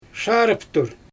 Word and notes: ʃaarɯptur: Xyzyl

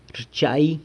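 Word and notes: rchai: San Lucas Quiaviní Zapotec

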